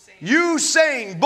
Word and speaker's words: Usain: Usain Bolt.